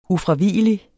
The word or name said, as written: ufravigelig